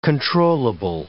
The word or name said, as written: controllable